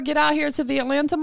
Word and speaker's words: Atlanta: It's Atlanta. That's how they say it out here. Atlanta. Atlanta. Atlanta. – Yeah. You don't even got a T in there. It's just Atlanta.